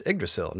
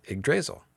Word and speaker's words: Yggdrasil: It's Yggdrasil, not Yggdrasil.